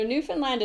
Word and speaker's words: Newfoundland: It's called Newfoundland and Labrador, and they both get final stress. And I think that helps a little to remember how to say it.